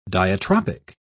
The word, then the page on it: diatropic: British English